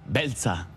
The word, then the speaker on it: Beltza: How do you say black in Basque? Belza. Black is Belza.